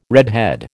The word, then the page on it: redhead: American English